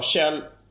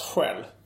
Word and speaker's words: Kjell: Att folk då skulle sa att det skulle uttalas själv.